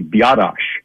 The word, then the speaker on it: Biadasz: So, you know, the pronunciation is actually Biadasz.